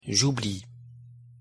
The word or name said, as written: j'oublie